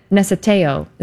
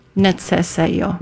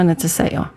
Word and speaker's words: necesejo: So the C is pronounced like a TS sound. – Okay. – Neceseo. – Neceseo. Okay. Mm-hmm. – And the J is like a Y sound.